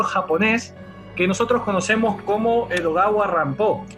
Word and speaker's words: Edogawa Rampo: Tiene que ver con algo que hablamos en el Zoom pasado con Edgar Allan Poe, ya que Edogawa Rampo es la forma en que el nombre Edgar Allan Poe se pronuncia en japonés.